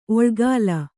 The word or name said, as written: ಒಳ್ಗಾಲ